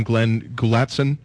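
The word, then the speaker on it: Glenn Gulutzan: In Dallas, they hired Manitoban Glenn Gulatson. Yeah. Is that how you pronounce his name? – It's pretty close.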